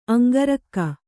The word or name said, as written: ಅಂಗರಕ್ಕೆ